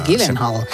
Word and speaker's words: Gyllenhaal: Jake Gyllenhaal.